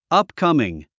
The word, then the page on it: upcoming: ʌ́pkʌ̀mɪŋ; アップカミン